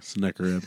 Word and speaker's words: Sennacherib: And when Hezekiah saw that Sennacherib, did I say that right? – Sennacherib, that'll work.